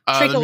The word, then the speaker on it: treacle: Treacle, like treacle tart.